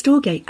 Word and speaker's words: storge: is it storge? – I always say storge, but I don't know if I pronounce it right. – Well, of course, it's ancient Greek, how would we know? It's storge, and we talked a lot about storge, unconditional love.